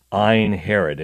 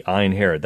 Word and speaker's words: Ein Harod: Now, in the Valley of Jezreel, there's this spring that rises up called Ein Herod. It has nothing to do with King Herod. It's spelled differently. E-I-N-H-A-R-O-D, Ein Herod.